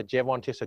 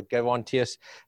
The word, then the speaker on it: Gerontius: It can be pronounced either Gerontius or Gerontius, and the Latinists will argue with you ad nauseum on the topic.